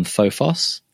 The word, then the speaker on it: Phophos: phophos that is i a m p h o p h o s and that is the correct pronunciation of Phophos for all streamers and podcasters out there.